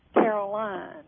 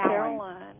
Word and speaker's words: Caroline: Okay, and your name is Carolyn? – Caroline. – Caroline. Okay. – Caroline. It's spelled L-I-N-E, Caroline.